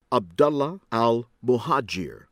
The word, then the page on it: Abdullah Al-Muhajir: American English